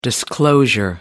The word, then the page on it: disclosure: American English